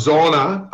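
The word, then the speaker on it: Zoona: What, is Zuna? – Hi, Bruce. Thank you very much for having me on the show. Zuna is actually pronounced Zona.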